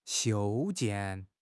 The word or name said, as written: 修建